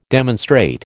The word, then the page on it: demonstrate: American English